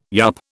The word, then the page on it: yup: British English